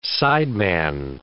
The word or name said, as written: sideman